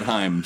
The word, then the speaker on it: Haim: They pronounce it Haim.